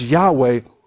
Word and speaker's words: Yahweh: which is pronounced Yahweh,